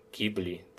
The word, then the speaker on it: Ghibli: E, studia Ghibli, tak? Tak to się wymawia.